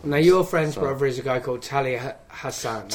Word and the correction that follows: Talha Ahsan: Talha Ahsan. That's right. Yeah, I didn't know how to pronounce.